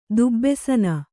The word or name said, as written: ದುಬ್ಬೆಸನ